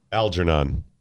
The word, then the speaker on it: Algernon: I don't think you pronounced that one right. – Algernon. – Yeah. Is it Algernon? Yeah, that's like the book Flowers for Algernon.